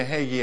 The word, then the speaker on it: Haggai: Everyone say Haggai. Haggai. The book of Haggai. That's how Brother Hagin would pronounce it.